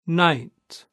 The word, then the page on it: night: British English